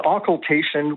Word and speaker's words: occultation: Is it an occultation? Am I pronouncing that correctly? – It's an occultation, which sounds like, I don't know, something out of a horror movie.